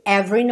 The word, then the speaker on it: every: O E do meio ali de every não é pronunciado, tá? Every. Pensa VR.